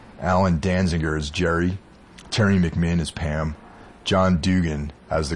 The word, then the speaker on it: McMinn: Terry McMinn. I almost mispronounced her name there. – Uh huh, McMinn.